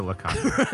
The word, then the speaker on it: LeCocq: Bally's topless show, Jubilee's longtime company manager, Fluff LeCocq, has... I probably mispronounced that. I think I mispronounced that. – It's LeCo, but I wanted to see if you would pronounce it LeCocq.